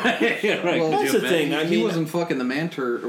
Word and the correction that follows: mantids: Mantids. – Mantids.